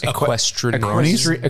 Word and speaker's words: equestrians: Equestrians. – Equestrians? – Yes. – Yeah. I said weirdos. – I said equestrianarian. – Equestrianarian school. – Just added a couple of syllables there.